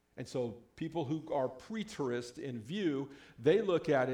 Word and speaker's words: preterist: Everybody say preterist. Yeah, I don't know if I'm saying it right either. But it's preterist.